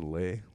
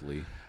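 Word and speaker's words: Leigh: I think still it's pronounced Lee. It's L-E-I-G-H. I assume it's Lee. – Lee, yeah. – I said Lay, Lee. I don't fucking know. – It's Lee.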